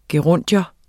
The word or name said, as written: gerundium